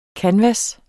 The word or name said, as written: kanvas